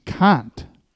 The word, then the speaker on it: Cant: It's Cant."